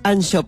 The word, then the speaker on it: Anxo: Y son 88 capítulos o digamos, sí, una forma de capítulos, donde el autor, que se llama Anxo Pérez, Anxo se escribe A-N-X-O, va mostrando distintas situaciones para la reflexión.